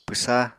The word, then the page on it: pwsa: Apatani